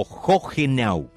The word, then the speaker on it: Hohenau: Hohenau o Hohenau, que me dijeron desde Alemania que se pronuncia así,